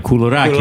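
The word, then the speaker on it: koulourakia: Now put it all together. Kulurakia. – Kulurakia.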